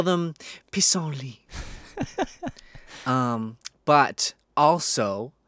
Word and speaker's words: pissenlit: I'm gonna take a stab at the pronunciation of pissenlit. P-I-S-S-E-N-L-I-T.